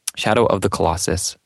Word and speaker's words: Shadow of the Colossus: Yeah, Shadow of the Colossus.